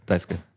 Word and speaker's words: Daisuke: Daisuke. Ja. Das wird immer sehr kurz ausgesprochen.